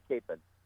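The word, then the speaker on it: Kapaun: So pick up where you left off with, now, which way do you prefer to pronounce his name? – I call him Father Capon.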